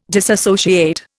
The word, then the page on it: disassociate: Indian